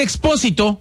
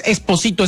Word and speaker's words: Expósito: Señor, vamos a iniciar con el asunto que trae Esther Expósito. que mucha gente me corrigió y ahora lo digo bien, no es Esposito, es Expósito.